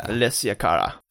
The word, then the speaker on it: Alessia Cara: You're not even saying her name right. – I know. I know I slaughtered it as soon as it came out of my mouth. – It's talking about some Madeline. Hurricane Madeline. – How do you say it? Alessia. – Alessia Cara. I mean, I'm probably saying it wrong too. Who knows? But that's what I've heard. And I've heard her say it like, it's Alessia Cara, like on the radio, you know? They say their names.